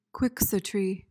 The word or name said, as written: quixotry